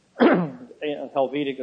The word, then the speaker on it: Helvetic: Helvetic is spelled H-E-L-V-E-T-I-C.